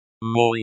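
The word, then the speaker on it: мой: This word is pronounced like moi, rhymes with boy.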